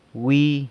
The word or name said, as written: wi